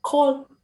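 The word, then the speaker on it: Kohl: cái từ bắp cải trong tiếng đức đọc là call và số nhiều là caller nó khá nghe nó khá là giống từ cola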